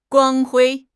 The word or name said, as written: 光辉